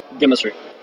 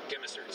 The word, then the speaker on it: Gemmacert: Gemmacert. – Gemmacert. So that's spelled G-E-M-M-A-C-E-R-T.